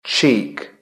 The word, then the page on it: cheek: British English